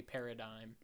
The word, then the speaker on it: paradigm: And then I Googled it, because I'm like, have I been pronouncing this word wrong my entire life? Or is this person the crazy? And it's definitely paradigm.